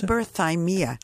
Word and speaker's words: Hyperthymia: How do you pronounce Hyperthymia.